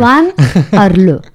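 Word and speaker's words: Swann Arlaud: Swann Arlo. Swan, como el camino de Swan de Marcel Proust.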